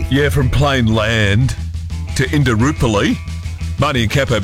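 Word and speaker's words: Plainland: I always call it Plain Land, but it's actually Plain Land, which doesn't sound right. – There's no S.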